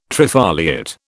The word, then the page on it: trifoliate: British English